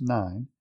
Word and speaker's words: Nain: And it came about soon afterwards that he went to a city called Nain, N-A-I-N, but it's pronounced Nine, and his disciples were going along with him, accompanied by a large multitude, thousands there.